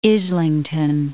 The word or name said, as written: Islington